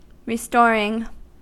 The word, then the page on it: restoring: American English